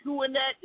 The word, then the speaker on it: HWUNET: And Huonet is his organization, and it's spelled H-W-U-N-E-T.